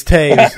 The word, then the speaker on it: Taves: Garrett Taves, I'm going to say his name right because I met him at the lake, and I, you know, even though it's spelt Toes, it's Taves, and I'm actually going to say it right.